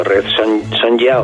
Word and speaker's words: Sonja: No, que sí, Red, digo para el que lo busque, que se escribe Red Sonja con J. – Una J de jamón. – De jamón, sí, en castellano, ¿no? Para el que lo quiera buscar en alguna librería y tal. Uh-huh. Aunque se debe pronunciar como estamos diciendo, Red Sonja o Sonja.